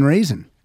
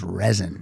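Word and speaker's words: Rezin: My friend Brian Raisin. Funny, his name is pronounced Raisin, but it's R-E-Z-I-N, which I would pronounce as resin. – Mm-hmm. – But he said it's Raisin.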